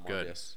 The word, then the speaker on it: Morbius: Speaking of Venom, Morbius. Am I saying that right? – Yes. – Oh, Morbius.